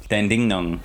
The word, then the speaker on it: Dandenong: They run competitions at Flemington, Hawthorn, and a place I know nothing of, Dandenong. Am I saying that right? – Yeah, I believe so. – Dandenong.